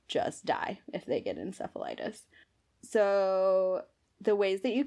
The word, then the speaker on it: encephalitis: or I'm going to pronounce this wrong, encephalitis, which is an inflammation of brain tissue. – I think you actually pronounced that correctly.